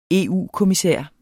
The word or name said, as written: EU-kommissær